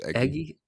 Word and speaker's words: Eguy: Do you know how to pronounce this person? – It's Eggy.